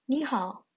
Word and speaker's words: 你好: in Chinese goes like this: "ni hao."